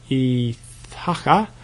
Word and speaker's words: Ithaka: It says, how do you say that? Ikaka? – Greek poem. Greek poem by Constantine Cave, titled Ithaka. You've got to be careful with that pronunciation.